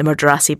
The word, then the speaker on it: Murdarsai: The Mardrassi, by the way, is spelled M-U-R-D-A-R-S-A-I, but I'll put a link in the show notes.